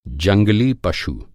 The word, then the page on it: पशु: پشو